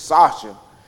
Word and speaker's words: Sasha: So, and, and, and, and the name spelled S A S H A, which is Sasha, but it was her name was pronounced Sasha. She said it was a German pronunciation.